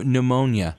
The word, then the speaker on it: pneumonia: No, pneumonia. – Pneumonia with a P. – With a P, and the P is silent.